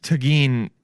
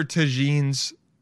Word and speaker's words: tagine: Anyone who has, because tagine would be, anyone who has nine miniature tagines, like that's how you say it, right? Tagine? Yeah. Tagine.